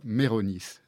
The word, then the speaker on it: Meyronis: Le titre de ce livre, c'est « Tout autre, une confession », et l'auteur s'appelle François Méronis. Ça s'écrit M-E-Y-R-O-N-I-S.